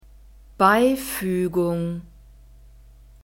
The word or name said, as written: beifuegung